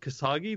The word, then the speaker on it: Khashoggi: I just read it 100 times, but the Cass Hoggy killing. – I don't even know what that means. – Oh, Jamal Khashoggi, the guy who was killed, the journalist in Turkey.